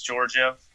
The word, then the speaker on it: Georgiev: I guess that's Alex Georgiev.